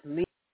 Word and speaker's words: Lima: Despite the origin of the name, to the bean, the word lima, is well, we say lima bean, but Peru it's lima. Even though we say lima, in Peru you say lima. is generally pronounced differently from Peruvian capital. We say Lima, they say Lima.